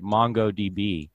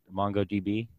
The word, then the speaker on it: MongoDB: If you get these big spikes to the upside, like look at MongoDB, if I'm pronouncing that right. You guys know how you pronounce that. Is that right? MongoDB? – As far as I know, that's right. – Okay. It's not Mongod.